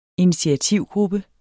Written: initiativgruppe

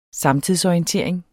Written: samtidsorientering

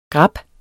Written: GRABBEN

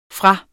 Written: fra

